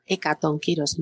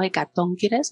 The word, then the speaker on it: hecatonquiros: Tengo aquí apuntado los hecatonquiros y aquí, mirad que lo pronuncia así en Diana, hecatonquiros, no hecatonquires, como se dice a veces. Ya sabéis por qué, por la ley de la penúltima tal.